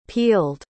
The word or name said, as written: pealed